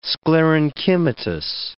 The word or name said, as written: sclerenchymatous